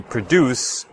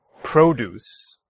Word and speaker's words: produce: So we can hear in the verb form produce, the stress is on the second syllable, deuce. Okay? So produce. All right. However, if I switch the stress to the first syllable, produce. Okay, one more time. Produce. Well, that switches it to a noun form, and that means like fruits and vegetables that you find at the grocery store, the supermarket.